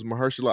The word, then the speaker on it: Mahershala: That was Mahershala Ali. And thank you for saying his name, because I'd be butchering the shit out of him. – Yeah, Mahershala Ali.